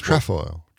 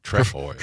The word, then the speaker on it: trefoil: How do you say it? Trefoil. Trefoil. Pretty straightforward once you know. Trefoil. Trefoil. – Trefoil. Trefoil. – Trefoil. Accents on the second.